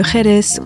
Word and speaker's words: Jerez: Combien de fois avons-nous entendu de tétinger prononcer « tétinger » au lieu de tétinger, codestournel ou collabori sans marquer le S, prononcer le X et le R de Jerez au lieu de la prononciation à l'espagnol, etc.